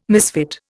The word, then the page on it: misfit: Indian